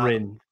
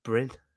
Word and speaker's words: Bryne: Because I thought when you said like Brown, and I was like, if I'd have just looked at it without looking at the pronunciation, I'd have been like, oh, maybe that's what it is. But Bryn. – Bryn. B-R-Y-N-E.